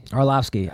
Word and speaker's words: Arlovski: Is it Arlovski or Arlovski? Do you pronounce the V? – Arlovski. – I always say Arlovski, but it's Andre Arlovski is fighting.